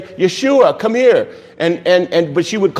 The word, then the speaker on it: Yeshua: The proper name, way to pronounce it, is Yeshua.